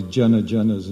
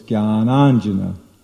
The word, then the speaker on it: jnananjana: We hear all these jana janas and gana ganas. And there's two words, jnana and anjana. Jnana, so it's jnananjana. Jnana means jnana, knowledge, and anjana means ointment. So the ointment of knowledge. It's not jana jana. Jana jana means people, people.